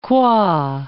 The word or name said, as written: qua